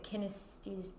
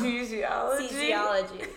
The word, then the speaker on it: kinesiology: I want to go back for, I can't even pronounce it, kinesthesia. You know, okay, like, yes, mm-hmm. I know what you're talking about. – You know what I'm talking about. I always say it wrong. – Kinesiology.